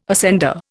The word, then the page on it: ascender: Indian